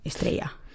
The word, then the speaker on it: Estrella: Jag älskar att du uttalar Estrella på spanska. – Estrella.